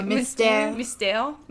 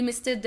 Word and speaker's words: Mystère: The Le Mystery is spelled M-Y-S-T-E-R-E. Le Mystery. How would you? – Mystère. – Yeah. – Le Mystère de la Chambre Jeune.